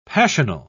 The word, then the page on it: passional: British English